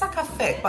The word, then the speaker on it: Sakafet: It's Sakafet, but because fet is the part, is the word for party, not so much emphasis on the T. So you say Sakafet, like.